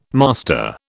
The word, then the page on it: master: British English